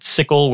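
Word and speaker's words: tsickle: And using TypeScript annotations, they have this plugin called Sickle with a silent T. It's pretty clever, but also like difficult to tell people, oh, look up Sickle and they'll type S-I-C. – No, it's T-Sickle. – It's T-Sickle, right.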